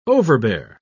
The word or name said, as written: overbear